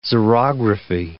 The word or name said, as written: xerography